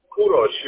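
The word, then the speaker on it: Kourosh: Kurosh, yeah, you are fine.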